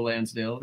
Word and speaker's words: Lansdale: He also wrote the screenplay for Bubba Ho-Tep, which is written by Joe Lansdale. – Which rhymes with Hansdale.